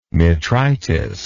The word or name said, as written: metritis